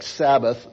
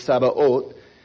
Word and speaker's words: Sabaoth: that's how it's pronounced, it looks like it would be pronounced like Sabbath, but it's Sabaoth, is our fighter.